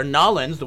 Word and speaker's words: New Orleans: I don't, because if you go to New Orleans or Nolens, the way it's supposed to be pronounced, Nolens.